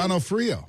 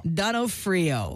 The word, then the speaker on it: D'Onofrio: How do you say his last name? – D'Onofrio. – D'Onofrio.